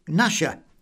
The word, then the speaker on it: Gnasher: Well, I'm hoping that you all probably knew that the answer we were looking for is Gnasher, with of course the silent G.